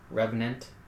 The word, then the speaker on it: Revenant: Alright, the Reverend starts its turn. Reverent, whatever, however it's pronounced. Revenant.